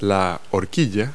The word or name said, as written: fork